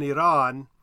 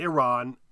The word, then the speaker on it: Iran: We're not speaking Arabic or Farsi, and I think Iraq and Iran, or Iraq and Iran, are fine. I mean, technically, in those languages, you sort of roll your r a little bit too least with Arabic.